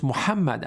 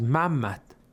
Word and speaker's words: Muhammad: For example, we cannot pronounce Muhammad as Mammat, or we can say Hussein or Hussein as, for example, Hussein or something like that in a shortened form.